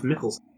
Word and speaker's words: Mickelson: It's usually pronounced Mickelson. It's spelled the same way.